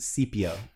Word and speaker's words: Scipio: He pronounces it Scipio.